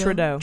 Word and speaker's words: Trudeau: No, yeah, I pronounced his name wrong. Is it Trudeau? – Trudeau. – Trudeau, yeah. I think Kushner would look more like that in my eyes if he was a good person.